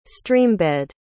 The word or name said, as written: streambed